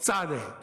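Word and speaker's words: tsade: That's a specific letter that's called tzade. That's how it's pronounced. It's the tz sound, tz, right?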